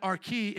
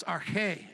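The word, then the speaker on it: arche (ἀρχή): We as scholars, we say it the wrong way, but the Greeks have to teach us. It's not arche, it's arche. Everybody say arche.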